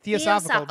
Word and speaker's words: theosophical: I think I'm saying theosophical. Now I've said yes. – it. I'm pretty sure.